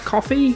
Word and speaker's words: Ko-fi: If you enjoyed that nonsense, you can support the podcast if you so wish on Ko-fi? Ko-fi? Coffee? How do you pronounce that? Coffee, right? – Yeah. – It's coffee.